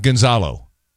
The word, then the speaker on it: Gonzalo: Gonzalo is not Gonzalo. It's Gonzalo.